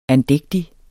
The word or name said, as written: andægtig